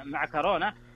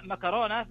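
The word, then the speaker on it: معكرونة: خلنا ناخذها أول شيء النطق الأصح يعني وش أو الأكثر شيوعا هو بالكاف طبعا مع كورونا، هذا النطق الصحيح ويمكن الدارج في أغلب الدول. أما مكورونا فهو نطق دارج منتشر في بعض اللهجات ويستخدم كثيرا في الحديث اليومي، لكنه يعني أقل يقولون أقل فصاحة من حيث التعريب المتداول.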